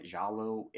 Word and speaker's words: giallo: You know, I think our second movie has a little bit of a giallo. Is that how you say it? Giallo influence on its lighting as well.